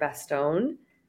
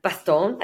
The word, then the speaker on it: Bastone: Okay, so I just finished the audiobook yesterday of Ready or Not by Cara Bastone. Bastone. I think that's how you say it. – I feel like it's like Gaston.